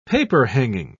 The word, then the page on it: paperhanging: British English